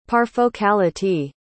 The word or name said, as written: parfocality